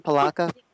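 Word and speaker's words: Polacca: Is that how you say her name, Palaka? – Yes. – Mona Palaka, yeah.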